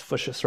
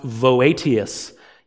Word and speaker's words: Voetius: Fuchsius, his name, yeah, it's Fuchsius, right? His name looks like it's pronounced Voetius, but a Dutch Reformed professor told me you say it Fuchsius, okay?